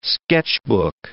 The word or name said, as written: sketchbook